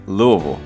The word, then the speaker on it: Louisville: Louisville. – Louisville. – Louisville. – Yeah. That's hard to say. – For those listening, it's Louisville, Louisville, or Louisville.